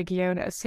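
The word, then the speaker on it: Ghionis: And he is very loosely inspired by my friend Jerry Guiones, who is one of the best wedding photographers in the world.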